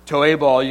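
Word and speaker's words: Toebal (תּוֹעֵבָה): More than that, we look at the original language, Toebal, you can see at the top, that's the, you know, the pronunciation as you would say it.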